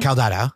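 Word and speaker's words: Caldato: A few more notes produced by Mario Calda. I'm going to say this wrong. – Caldada. – Thank you.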